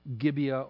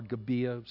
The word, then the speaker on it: Gibeah: And what we find here as you close out the book of Judges is we see this atrocity at Gibeah or Gibeah, some pronounce it differently.